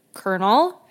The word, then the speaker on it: colonel: Why is there an L? – Why is it spelled like colonel? I don't know. but, but that's what it is in French.